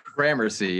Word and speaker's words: Gramercy: Gramercy Riffs. Am I saying that right? – Gramercy, yeah. The Gramercy Riffs.